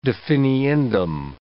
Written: definiendum

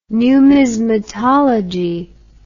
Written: numismatology

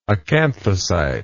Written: acanthocyte

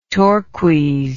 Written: torques

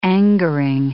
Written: angering

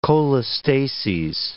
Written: cholestases

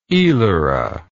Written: elara

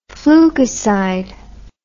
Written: flukicide

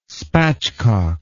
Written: spatchcock

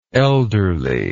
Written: elderliness

